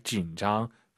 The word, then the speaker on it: 紧张: 진 올라가면 안 되죠. 뒤에가 일성이니까 요디알 진장 이런 발음도 중요한 것 같습니다.